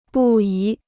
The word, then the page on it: 不宜: bù yí